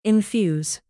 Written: infuse

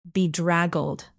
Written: bedraggled

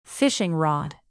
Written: fishing rod

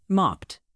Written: mopped

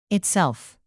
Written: itself

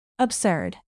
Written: absurd

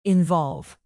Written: involve

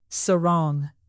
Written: sarong

